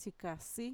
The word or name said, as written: txikasi